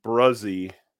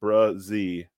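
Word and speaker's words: Bresee: He's a quality dude, played for a tremendous program down at Clemson, and pronounced Bruzzy is how you pronounce it. Bruzzy.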